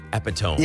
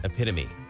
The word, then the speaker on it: epitome: How about this? Epitome. That, it looks like epitome. – Yeah, it does. Yeah. – But epitome. – Another word that is spelled F-A-C-A-D-E.